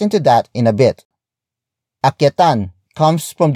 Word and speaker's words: akyatan: Akyatan mo ng ligaw si Stella.